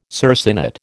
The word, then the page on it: circinate: American English